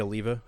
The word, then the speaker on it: Oliva: Jay Oliva, I should say. Sorry, mispronounced. – Oliva, yep.